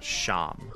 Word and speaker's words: shawm: It's spelled S-H-A-W-M, but it's pronounced sham.